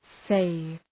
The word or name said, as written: save